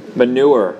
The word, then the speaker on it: manure: First, try giving them manner. That's the right pronunciation, right? Farmer's manner. Manure. Manure. I knew there was something wrong with that.